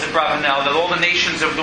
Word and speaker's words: Abravanel: Abravanel on that pasuk, as you may know is Abarvanel, but his correct name pronunciation is Abravanel on that pasuk says,